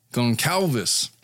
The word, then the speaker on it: Goncalves: Kaylee Goncalves, I've been pronouncing her name Gonclaves for the longest time. – Goncalves.